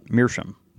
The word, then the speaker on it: Meerschaum: So over the last few months, hearing him say it every week has altered my pronunciation. And so Jason now says it more like me, which is Meerschaum.